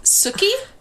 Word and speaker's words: Sookie: Sookie? – Sookie. – How do I spell it? – S-O-O-K-I-E.